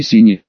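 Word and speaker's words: SHINee: В нашем мире также существует группа Шинье, кстати произносится название группы на русском как Сини или Сини.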